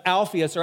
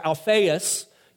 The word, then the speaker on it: Alphaeus: Now, he was the son of Alphaeus, or Alphaeus. You could pronounce it a couple different ways.